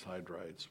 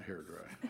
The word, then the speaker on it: hydride: Not hair dry. – Hydride. Mm-hmm. That's it.